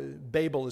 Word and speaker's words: Babel: or Babel. Babel is probably the more correct way to pronounce it, but it's interesting.